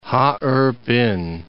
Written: Ha-erh-pin

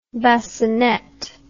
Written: bascinet

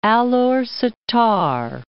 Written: Alor Setar

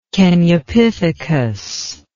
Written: Kenyapithecus

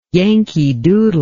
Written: yankee doodle

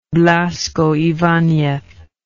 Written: Blasco Ibanez

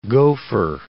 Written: gopher

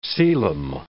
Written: coelom

